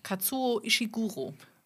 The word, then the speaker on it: Kazuo Ishiguro: Die KI hat es falsch ausgesprochen. Oh. Katsuo Ishiguro.